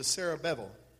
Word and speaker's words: Zerubbabel: Say this to Zerubbabel, that last B is pronounced V, son of Shealtiel, governor of Judah, and to Yeshua, son of Jehozadak, the high priest.